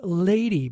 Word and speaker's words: LVDY: The wonderful duo called Lady, but they spell it LVDY, all in capital letters.